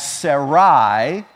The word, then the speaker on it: Sarai: Now, when we read some of our passages today, you're going to see her name as Sarai, S-A-R-A-I. So I'm going to pronounce it that way at that time when it's spelled that way.